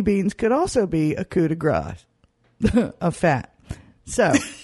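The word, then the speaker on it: coup de grâce: Peter in Atlanta wrote and said in his subject heading, "It's coup de grace, not coup de gras." You pronounce it. – Damn, he's right. – You pronounced it coup de gras, and that is wrong. It should be coup de grace. The key here is that gras, fat, is gras, and grass, grace, mercy, is grass.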